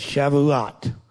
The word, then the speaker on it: Shavuot: I've been practicing how to say it right, but it's Shavuot.